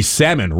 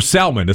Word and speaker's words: salmon: Did you know it's also the time of the salmon run? Or salmon, as some people like to pronounce the L in there. – Right. All the letters need to be heard.